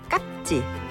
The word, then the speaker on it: 깍지: 연필 깍지, 발음은 깍지, 쓸 땐 깍지로 표기합니다.